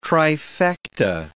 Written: trifecta

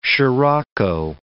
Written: sirocco

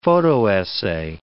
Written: photo-essay

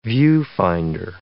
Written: viewfinder

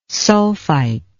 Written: sulfite

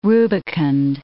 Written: rubicund

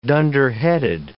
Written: dunderheaded

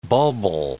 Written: bulbil

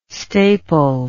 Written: staple

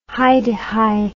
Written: hi-de-hi

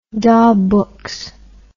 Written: daw books

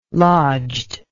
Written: lodged

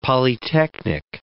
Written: polytechnic

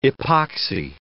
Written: epoxy

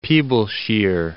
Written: Peeblesshire

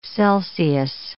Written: Celsius

